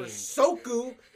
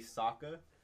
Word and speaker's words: Sokka: They're never given a reason to care for Soku.